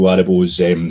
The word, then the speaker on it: Aribo: And it was lovely for his, anybody out there that had doubts about Joe Aribo's, well, everybody's got doubts about how I pronounce his name. Aribo, Joe Aribo.